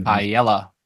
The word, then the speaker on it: Paella: Paella. – Paella. Yeah, Paella. The L's are pronounced like a Y.